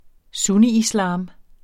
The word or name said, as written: sunniislam